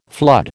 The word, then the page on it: flood: American English